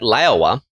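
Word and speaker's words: Laowa: I found it specifically, but it's been widely reported everywhere else that Liowa, that's how I pronounce it properly, that has their Venus Optics line of camera lenses, has just rolled out with a few new ones.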